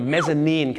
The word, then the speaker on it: mezzanine: mezzanine ครับผมนั่นแหละฮะ mezzanine ไม่ใช่ mezzanine นะครับ mezzanine เหตุผลที่เขาต้องออกเสียงว่า nine เนี่ยเพราะถ้าไปบอก mezzanine เดี๋ยวมันนึกว่าเป็นชั้น 9 เดี๋ยวจะทับกันได้